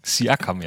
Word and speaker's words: Siakam: Siakam, ja.